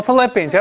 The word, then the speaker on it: Philippians: Now St. Paul, in his letter to the Filipinos, was there a slip on my tongue there? Didn't he write a letter to the Filipinos? Oh, Philippians.